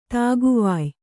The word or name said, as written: ದಿಕ್ಕುವಾಯ್